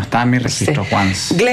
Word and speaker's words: Once: Se escribe Once, como en inglés Once. – Como Once Upon a Time.